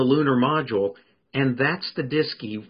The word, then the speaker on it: DSKY: So they developed something called the display keyboard, which they abbreviated DSKY, and they pronounced it DISKY.